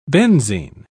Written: benzene

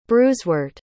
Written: bruisewort

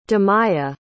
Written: dimaia

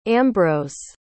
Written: ambros

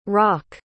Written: roch

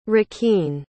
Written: riquin

